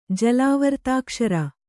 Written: ಜಲಾವರ್ತಾಕ್ಷರ

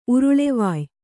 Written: ಉರುಳೆವಾಯ್

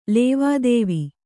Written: ಲೇವಾದೇವಿ

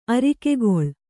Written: ಆರೈಕೆಗೊಳ್

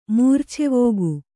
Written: ಮೂರ್ಛೆವೋಗು

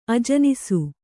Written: ಅಜನಿಸು

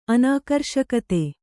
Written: ಅನಾಕರ್ಷಕತೆ

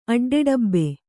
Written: ಅಡ್ಡೆಡಬ್ಬೆ

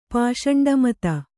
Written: ಪಾಷಂಡಮತ

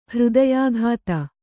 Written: ಹೃದಯಾಘಾತ

